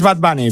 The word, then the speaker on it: Bad Bunny: Bueno, no sé si lo he pronunciado bien. Se dice así, la linterna de COPE Asturias, Pedro Rivero, muy buenas. – Muy buenas, es Bad Bunny, pero bueno, ya Bad sabemos, Bunny.